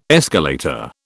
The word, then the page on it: escalator: British English